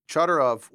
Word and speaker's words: Chodorov: One of his students, and you'll have to help me on the pronunciation, Frank Chodorov? – Chodorov. – It is Chodorov.